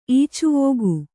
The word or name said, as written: ಈಚುವೋಗು